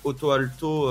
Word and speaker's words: Ottoalto: Oui, en plus, il y en a pour, j'invite, j'invite les auditeurs à aller sur autoalto.be euh, qui s'écrit O-T-T-O-A-L-T-O.be.